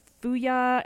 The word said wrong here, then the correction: Fujiya: and Miyagi, right? – Fujiya.